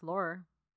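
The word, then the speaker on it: Laurer: Chyna was born Joan Marie Lohr in Rochester, New York. Right? – No? Lauer? – I heard it pronounced Lohr.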